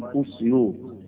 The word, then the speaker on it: أسيوط: في الأصل أو في الحق الكلمة اسمها الأسيوطي، وهي تنطق بضم الألف، ومن الصعيد لابد من نطقها نطقا صحيحا تقال أسيوط.